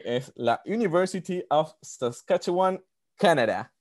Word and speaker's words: Saskatchewan: En la University of Saskatchewan, en Canadá. ¿Lo dije bien? – Casi bien. Pero Es un nombre, es un nombre, es un nombre de que, es súper difícil, se llama Saskatchewan.